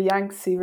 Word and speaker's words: Yangtze: Is it A, the Amazon, B, the Nile, C, the Congo, or D, the, I don't know how to pronounce this one, Yangtze? – The Yangtze River.